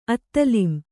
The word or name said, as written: ಅತ್ತಲಿಂ